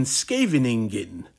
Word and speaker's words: Scheveningen: JAA began with the 1958 edition of Art Blakey's Jazz Messengers, recorded live in Scheveningen. I believe that's the way you pronounce that town in the Netherlands.